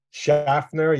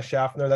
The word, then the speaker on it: Schaffner: Schaffner, yeah, Schaffner, that's pretty good. A lot of times I get a Schaffner, or people will just go with Schaefer because it's much more common, I suppose, right? – What do the Polish people say?